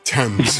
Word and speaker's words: Thames: Thames. – Him. And you just got 50 entries to win the Devils in London contest. You might be riding a boat on the River Thames.